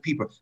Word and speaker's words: Pieper: Not Piper, it's pronounced Pieper.